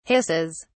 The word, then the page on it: hisses: British English